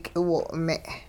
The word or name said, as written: k'iwo'-me'